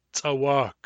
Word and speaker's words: tsawak: Tsawak. Tsawak. When you pronounce this word in a Nuchanov accent, the second syllable should be slightly stronger than the first.